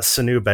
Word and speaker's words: Sinube: I would have guessed Sinube too, but it's Sanube. – Sanube. So there's an accent on the E. – Mm-hmm. No visible accent, but it's said that way.